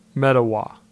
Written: Mettawa

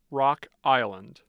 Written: Rock Island

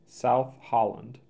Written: South Holland

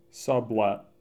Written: Sublette

